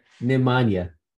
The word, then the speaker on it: Nemanja: And then the last guy, Namjoon Bialica. Yeah, he went in the second round here. – Nemanja. – Yeah, whatever. Butchered his first name, but got his last name right.